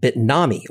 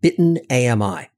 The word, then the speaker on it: Bitnami: One of the early folks, in fact, WordPress was one of the things that they wound up shipping, was a company called Bitnami, or as I insisted on mispronouncing it, Bitten AMI.